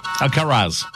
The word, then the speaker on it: Alcaraz: Carlos Alcatraz. Oh, no. Alcaraz. That's how you say it.